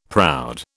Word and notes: proud: British English

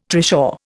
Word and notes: trishaw: Indian

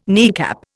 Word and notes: kneecap: Indian

